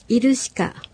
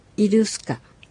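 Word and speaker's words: イㇽㇱカ: イルシカ。 – イルシカ。 – 怒るです。なお、この小さいシは、スという発音になることもあります。ウパシがウパス。イルシカはイルスカ。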